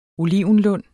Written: olivenlund

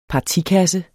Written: partikasse

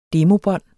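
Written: DEMOBÅNDET

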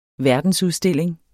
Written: verdensudstilling

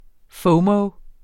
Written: FOMO

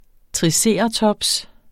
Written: triceratops

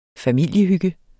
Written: familiehygge

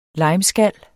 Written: limeskal

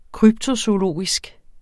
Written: kryptozoologisk

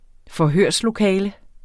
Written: forhørslokale

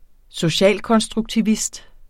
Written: socialkonstruktivist

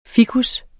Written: ficus

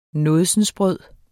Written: nådsensbrød

